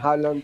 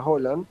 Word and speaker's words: Haaland: Ustedes dicen Haaland, pero se pronuncia Haaland.